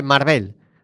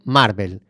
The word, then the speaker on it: Marvel: Me comenta que no se dice Marvel, se dice Marvel.